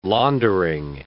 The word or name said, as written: laundering